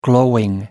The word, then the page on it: glowing: American English